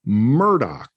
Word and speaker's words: Murdaugh: And the last name is Murdaugh. That's how it's pronounced.